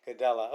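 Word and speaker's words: Gadelha: Alexia Grasso versus Claudia Gadella. Is that how you say it? – Gadella. – Okay.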